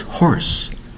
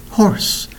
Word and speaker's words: hoarse: Today's word is horse. Horse. Not the kind you think. The word is horse. One syllable spelled H-O-A-R-S-E. That's right. H-O-A-R-S-E.